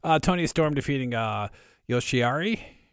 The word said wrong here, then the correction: Io Shirai: Io Shirai.